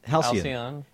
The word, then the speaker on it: Halcyon: Halcyon? – Halcyon.